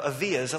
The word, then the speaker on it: Avia: And then on the way out, though, I saw a pair of Avias. At least I think they're pronounced Avia, A-V-I-A.